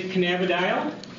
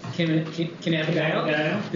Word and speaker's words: cannabidiol: I say cannabidiol. – Cannabidiol? Did I say that right? – Cannabidiol or cannabidiol are both correct pronunciations.